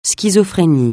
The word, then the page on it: schizophrénie: francais